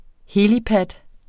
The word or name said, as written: helipad